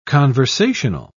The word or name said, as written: conversational